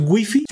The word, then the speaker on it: wifi: Escribe con W, I, F I y entonces la RAE dice, bueno, entonces nada más pronúncialo en su idioma original. Wi-Fi, Wi-Fi, de hecho, Wi-Fi. Y eso aplica con cualquier palabra del ruso, del, chino, del japonés, del suizo.